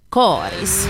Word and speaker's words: Karis: Så här svarar hon när jag frågar i vilka sammanhang hon säger Karis. – Det har nog aldrig hänt, tror jag. – Är det viktigt för Karisborna att man uttalar det med korta? – Jo, men nu tror jag det.